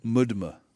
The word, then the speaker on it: MDMA: Mudma. – Mudma? – Mudma. Like MDMA. They just pronounce MDMA mudma.